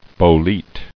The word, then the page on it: bolete: bo·lete